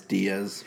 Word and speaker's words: Diaz: Manny Dyess. And it made sense. It was a common sense move. – How did you say his last name? – Diaz, whatever. Manny.